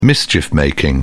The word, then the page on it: mischief making: British English